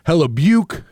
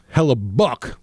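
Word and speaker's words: Hellebuyck: I said, hella Buke. It's hella Buck, the goaltender. I was pronouncing it with the CYK.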